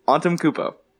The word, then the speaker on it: Antetokounmpo: Antetokounmpo. – There's no t-t. It's Antetokounmpo.